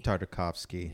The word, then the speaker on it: Tartakovsky: Tartakovsky.